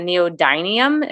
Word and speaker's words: neodymium: And that's the neodymium.